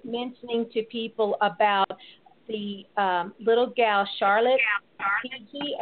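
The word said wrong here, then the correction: Figi: Yes, Charlotte Figgy.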